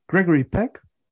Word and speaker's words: Gregory Peck: Gregory Peck.